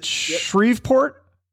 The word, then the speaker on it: Shreveport: Is it Shreveport, Louisiana? – Yep, you got it.